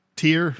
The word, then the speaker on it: Tyr: I think he's pronounced... Is it Tyr? – Tyr.